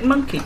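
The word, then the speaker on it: Mynci: You pronounce that word monkey? – It's Welsh.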